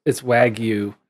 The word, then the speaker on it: Wagyu: This is connected to last week's discussion of, did we decide if it was Wagyu or Wagyu? – Yes. – It's Wagyu. I think it's pronounced Wagyu.